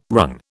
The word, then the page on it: wrung: British English